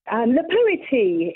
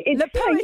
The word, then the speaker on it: La Poeti: Did I say that wrong or not? – La Poeti. La Poeti. You're pretty much right.